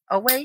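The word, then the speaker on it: Oweh: and that's Jason Owe. Is that how you pronounce it? Owe? – Owe. Yeah, Owe. Jason Owe.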